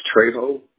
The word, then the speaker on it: Trejo: It's Trejo. You spell it T-R-E-J-O.